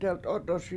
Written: delt7Ot7oSi7

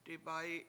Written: dibAyi7

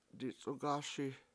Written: dic7ugASi

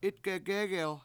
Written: 7itgegEgel

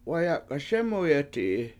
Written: wayAk7aSEmuyeti7i

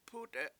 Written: p$UUte7

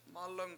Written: mAlung

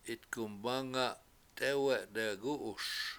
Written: 7itgumbAnga7 t7Ewe7 dewgU7uS

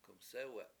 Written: k7umsE7we7

